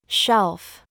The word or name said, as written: SHOE